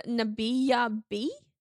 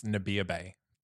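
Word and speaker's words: Nabiyah Be: Eurydice was Nabiya B. – I believe it's Nabiya Bay. – Oh, God. – I honestly, I've looked it up. And that was the pronunciation that was given in the YouTube thing.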